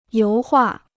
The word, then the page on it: 油画: yóu huà